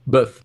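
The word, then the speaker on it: bœuf: Então, como se fala boi em francês? Buff.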